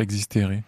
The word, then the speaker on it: Existere: Alors expliquez-nous ce titre « Existere ». Si je prononce bien. – Oui, tout à fait. C'est du latin.